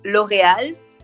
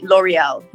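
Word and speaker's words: L'Oréal: Well, in French is L'Oreal. And in English, you would say L'Oreal.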